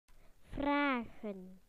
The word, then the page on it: to ask: British English